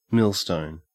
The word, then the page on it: millstone: Australian English